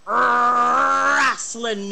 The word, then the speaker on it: Rasslin: So put on your Speedo and cue up your entrance music, because it's time once again for the Pope on Film Rasslin News. With an emphasis on the R. It's not the Pope on Film Wrestling News, it's Rasslin News. There's no G in that at all.